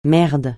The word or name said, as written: merde